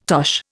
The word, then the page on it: tosh: Indian